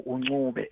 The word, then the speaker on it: Ncube: My surname Ncube, and you find people saying Ncube, Ncube. I always insist ukuthi the minute you say Ncube, you are not referring to me.